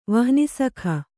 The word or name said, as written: ವಹ್ನಿಸಖ